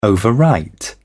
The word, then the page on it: overwrite: British English